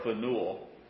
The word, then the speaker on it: Phanuel: There was also a prophetess, Anna, the daughter of Phanuel. Did I say that right? – Sounds good to me.